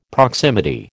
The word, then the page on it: proximity: American English